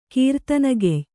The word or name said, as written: ಕೀರ್ತನಗೆಯ್